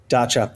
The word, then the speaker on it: Dacia: Never known how to pronounce that. You might correct me. Is it Dacia? – Dacia.